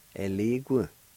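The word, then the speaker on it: yeligu: It's pronounced like this, A-N-A-T-E-L-A-D-I-S-K-I. A-N-A-T-E-L-A-D-I-S-K-I. Onateladiski. One of them two, enough. It's pronounced like this. Ye li gu. Ye li gu. Ye li gu. Sometimes you might hear people saying e li gu.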